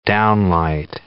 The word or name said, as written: downlight